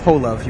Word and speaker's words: Holove: Jeff Holove. You got it exactly right. – H-O-L-O-V-E.